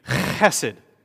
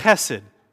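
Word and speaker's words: Chesed (חסד): Say it with me. Chesed. What should the person on the in front of you feel on their neck? Spit. Okay, chesed. That's how you say it. Chesed.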